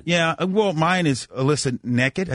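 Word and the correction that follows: Nakken: It's Alyssa Nacken.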